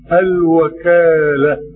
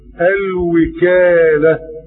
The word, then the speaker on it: الوكالة: هذا كان يعني أول فائدة: الوكالة بفتح الواو. والفائدة الثانية: الوكالة، الكلمة تنطق بالفتح والكسر وكل منهما صحيح.